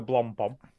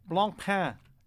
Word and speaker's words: Blancpain: I'm not comfortable with the way you're saying Blompom. – No, it's spelled Blancpain. – Blancpain. Jesus. – No, it's pronounced Blancpain. – It's totally Blancpain. – Not. – It's French.